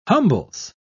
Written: humbles

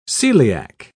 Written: celiac

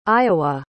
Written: iowa

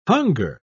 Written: hunger

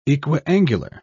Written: equiangular

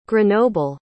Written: grenoble

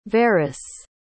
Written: veras

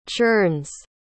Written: churns

